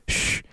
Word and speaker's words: Ssssh: It literally is called S-S-S-S-H. It's pronounced Shh.